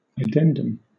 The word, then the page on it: addendum: British English